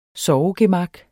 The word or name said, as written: sovegemak